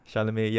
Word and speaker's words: Chalamet: Yeah, do you guys see this meme where it's basically him and Timothy Shamala? – Softboy Chalamet. – Softboy. How do you pronounce his name again? – Chalamet, yeah.